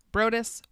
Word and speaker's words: Broaddus: They mention the, how would you pronounce this fucking name? B-R-O-A-D-D-U-S. Brodus.